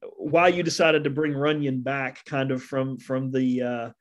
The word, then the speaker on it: Runion: Runion.